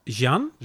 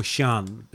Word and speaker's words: Xi'an: How's it pronounced? – Xi'an. Or Xi'an is probably closer.